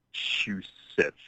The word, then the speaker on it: Massachusetts: I've had a few beers and I can't pronounce the word Massachusetts. Massachusetts. There we go.